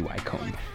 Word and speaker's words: Wycombe: You know, I think it shows up because it's pronounced incorrectly. It should be Wycombe.